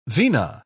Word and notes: vina: British English

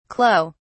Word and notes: clou: British English

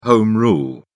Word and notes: home rule: British English

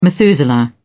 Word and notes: methuselah: British English

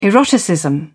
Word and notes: eroticism: British English

